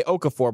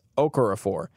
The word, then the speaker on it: Okorafor: You want to say Okafor, but that's not it. It's Okorafor. So I'm excited to see him at the Senior Bowl because I think he's a guy that can actually really rise.